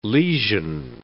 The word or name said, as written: lesion